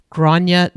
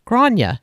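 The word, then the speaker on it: Gráinne: And we started it all off with Grania Duffy. I'm pretty sure I'm pronouncing the name correctly. It's spelled G-R-A-I-N-N-E. Grania Duffy.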